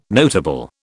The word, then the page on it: notable: British English